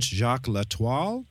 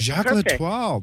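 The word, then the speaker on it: Jac L'Etoile: But this is your third book featuring, and I'm going to mispronounce her name because it's French, Jacques L'Étoile. Did I get it right? – Oh, no, that was perfect. – Jacques L'Étoile.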